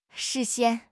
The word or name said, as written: 视线